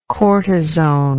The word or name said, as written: cortisone